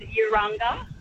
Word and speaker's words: Urunga: I'm just driving past Vala and I thought I should call in and say it's pronounced Vala and Urunga and Ginungay Way.